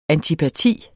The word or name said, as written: antipati